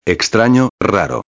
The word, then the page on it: extrano/raro: espanhol